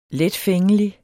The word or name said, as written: letfængelig